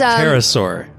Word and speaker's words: pterosaur: It's a pterosaur, I think. – Pterosaur. – They call it a petrosaur in the animal, but is there a letter between the P and the T? – Oh, no. – That's my question. – No, it's just a P and then a T.